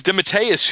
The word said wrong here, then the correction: DeMatteis: who was, or Dematis, that's how his name's pronounced.